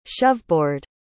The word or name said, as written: shoveboard